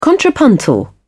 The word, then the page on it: contrapuntal: British English